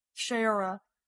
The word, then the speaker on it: Shayura: So I just worry because we've seen what happened to Soraya. I said her name wrong again. Frick frack. Um, Shayora and we've seen what happened to uh, Aisha.